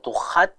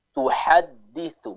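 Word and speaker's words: tuhadditsu (تُحَدِّثُ): Tadi yang Ummu Abdillah baca, huruf ha-nya masih kurang jelas sehingga tercampur dengan suara dari huruf kha. Tuhad. Nah, ini kurang tepat, bukan tuhad tapi tuhadditsu, bersih suaranya ya.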